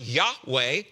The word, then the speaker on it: Yahweh: And notice that Jehovah is an English rendering of the Hebrew name for God, represented by four consonants, YHWH, which is pronounced Yahweh.